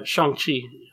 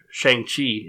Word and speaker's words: Shang-Chi: We also saw Shang-Chi is how they pronounce it in the movie. Or Shang-Chi, if you're a white American.